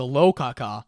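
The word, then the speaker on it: Lokakaka: I still don't understand what the fuck the rokaka does. Sorry, the lokaka. – Rokaka? – Lokaka. It's spelled with an L.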